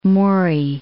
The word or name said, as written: Maury